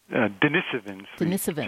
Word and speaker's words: Denisovans: That is, getting genomic information from some of our extinct ancient cousins, like the Neanderthals, and I'm not sure how to pronounce this, Denisovans? – Denisovans. Generally say, but people say it different ways.